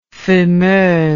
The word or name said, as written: fumeuse